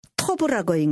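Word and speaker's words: 토브 (טוֹב): 돕은 히브리어로 토브라고 읽는데요.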